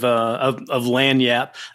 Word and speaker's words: lagniappe: Lagniappe. The other is lagniappe. – Right. You know, phonetically, it would be L-A-N-Y-A-P.